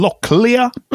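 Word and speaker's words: Lochlea: Men eh, David, du ska få prata om någonting som uttalas som locklia.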